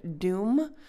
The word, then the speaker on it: Dum: Today's mantra is the word doom. And I've done this one before, but it's spelled D-U-M, and it is Durga's Bija mantra.